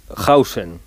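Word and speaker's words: Huijsen: Se escribe Huiksen, pero se dice Hausen, ¿vale? – Vale.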